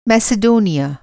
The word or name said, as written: मैसेडोनिया